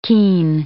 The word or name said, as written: keen